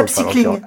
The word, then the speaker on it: upcycling: Alors, le projet, c'est de se réunir autour du vêtement de seconde main, du upcycling, si je le dis bien correctement, je ne sais pas. – Non, c'est pas mal, c'était mieux que moi, je m'y avais aventuré. – Upcycling. – Upcycling, très bien.